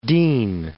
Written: Dean, Forest of

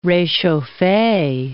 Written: rechauffe

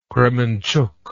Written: Kremenchug

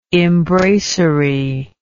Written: imbracery

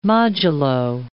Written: modulo

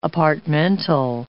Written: apartmental

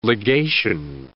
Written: legation

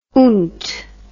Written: oont

